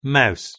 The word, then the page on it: mouse: British English